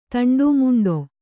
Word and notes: ತಂಡಾಮುಂಡಾ: taṇḍāmuṇdā

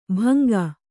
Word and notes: ಭಂಗ: bhanga

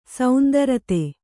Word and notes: ಸಾಂದ್ರತೆ: sāndrate

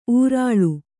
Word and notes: ಊರಾಳು: ūrāḷu; Kannada

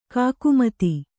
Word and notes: ಕಾಕುಮತಿ: kākumati

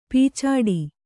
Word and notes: ಪೀಚಾಡಿ: pīcāḍi

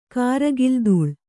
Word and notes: ಕಾರಗಿಲ್ದೂಳ್: kannada

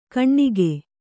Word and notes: ಕಣ್ಣಿಗೆ: Kannada

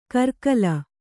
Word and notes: ಕರ್ಕಲ: karkala; Kannada

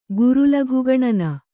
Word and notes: ಗುರುಲಘುಗಣನ: guru laghu gaṇana

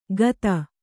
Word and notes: ಗತ: gata

